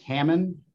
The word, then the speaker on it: Hammond: Justice, it's Hammond, like Hammond. The meat and the woman's name, okay. Ham Ann.